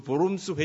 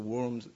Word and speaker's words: Worms: 예, 이것이 뭐 루터가 직접 한 말인지 아닌지에 대해서는 약간 분분합니다만은 그걸 떠나서 어쨌든 이 사건 앞에서 소위 그 보름스 회의라고 하는데, 독일어 발음으로 영어로 이제 worms 이렇게 되겠지만은 Diet of Worms에서 황제 앞에서 굴하지 아니하고 당당하게 자기의 믿음을 소신껏 지켰다라고 하는 유명한 사건이 있습니다.